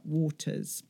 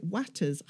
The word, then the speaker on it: Waters: Now, before we come on to Brian's book, the last other book I'm going to talk to you about is Affinity by Sarah Waters. I always want to pronounce it Watters.